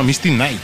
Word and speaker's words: Misty Knight: Pues Misty Skin... – Misty Skin, no, Misty Knight, Sonia.